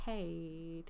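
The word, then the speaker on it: hei: It's hey. – On mic. Oh, it's H-E-I, right? – Hey or hi. How do you say it? – You know how it's similar to how they say it in Sweden, because I...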